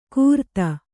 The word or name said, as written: ಕುರ್ತಾ